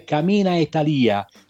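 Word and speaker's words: Cammina e Talìa: abbiamo voluto dire Cammina e Talia, cammina con la I accentata e Talia.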